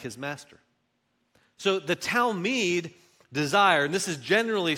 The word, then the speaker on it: talmid: So in the Hebrew, the word for disciple is talmid. Let's everybody say that together. Everybody say it. Ready? Set, go. Talmid.